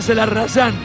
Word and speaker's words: Zelarayán: Lucas Zelarayán o Zelarayán, como le gusta a él que le digan. Yo lo pronuncio en armenio porque me gusta jugar con eso, pero a él le gusta que le digan Zelarayán.